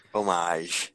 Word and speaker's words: homage: I know there's an H, but you don't pronounce it. Homage. – Homage. – Don't dishonor the H. – You don't announce it. – What? – What? – Homage. – Homage. Like herb.